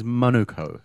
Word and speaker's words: Manukau: The correct pronunciation is Manukau.